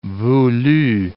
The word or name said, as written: voulu